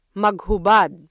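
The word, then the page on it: maghubad: Filipino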